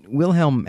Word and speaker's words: Wilhelm: but Kate Wilhelm is an American writer, so she pronounces the W like a W and not like a V. Kate Wilhelm had a great career.